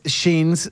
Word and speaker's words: jeans: En inglés, jeans se pronuncia igual, suena fonéticamente igual que genes,